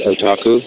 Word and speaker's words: otaku: Am I saying that right, otaku? – Yeah.